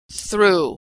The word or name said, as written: threw